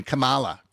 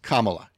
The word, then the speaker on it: Kamala: A comma followed by la, L-A, as in comma la. Because he had his fill, like a lot of us, of people intentionally mispronouncing the vice president's name, going Kamala, Kamala, Kamala. It's Kamala. You've had plenty of time to get it right.